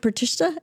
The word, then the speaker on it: pratishtha: I think for me, it's like, I think it's sense gratification and, you know that word pratishtha? Am I saying it wrong? – No, I think you're saying it right.